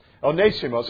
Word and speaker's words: Onesimus: The word Onesimus, the name Onesimus, Onesimus is how you pronounce it, if I could continue to do that consistently, means profitable.